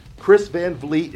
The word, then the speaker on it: Chris Van Vliet: Chris Van Vliet, it rhymes with meat.